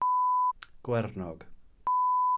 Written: gwernog

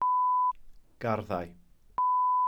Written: garddau

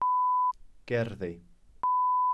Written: gerddi

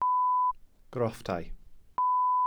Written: grofftau

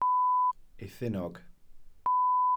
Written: eithinog

